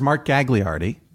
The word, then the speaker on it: Mark Gagliardi: So, first now, of all, I should clarify, it's pronounced Mark Gagliardi, and we had him on. No, that's fine. I mean, you were pronouncing it in the proper Italian way, you know what I mean?